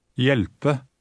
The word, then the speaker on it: hjelpe: Read it as if the H were not there. – Hjelpe. – Note that when a word begins with an H-J combination, the H is silent.